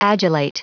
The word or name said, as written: adulate